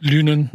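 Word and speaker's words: Lynen: Im Gegenteil, das Zentrum stand nachher am Ende mit äh, Linen, Linen, wie spricht man ihn aus? Lünen. Lünen, Lünen.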